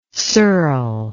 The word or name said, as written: Searle